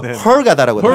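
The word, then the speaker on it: Hurghada: 영어로 또 헐가다라고. 네. 헐가다. 네. 네. H-U-R 그렇게 들어가더라고요. 네. 발음. – 헐게다. 네. – 헐게다. 개까지 안 가요.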